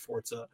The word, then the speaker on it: Forza: Like, they tweeted out that, like, we want everybody to know what's pronounced Forza.